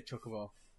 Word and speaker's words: Chocobo: At the Chocobo farm, the right way to say Chocobo. – What? – Throwing shade. Chocobo.